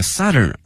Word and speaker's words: southern: Hangzhou is both the southern end of Beijing Hangzhou Grand Canal，京杭大运河，杭州是它的这个最南端了，用了这个 end， E-N-D，同时前面用这个 southern 而不用这个 south，用它的这个南部的南端的形容词，同时这个发音大家已经注意到了，是 Southern 小口型的啊音，而不是这个 South 啊大口型的这个 ow，